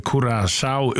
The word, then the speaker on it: Curaçao: Ja, och vi kommer att säga att landanavnet Curaçao uttalas Cura, K-U-R-A-S-A-U av vårskan Curaçao.